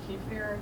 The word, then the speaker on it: kefir: Or there's like kefir. That's how you say it.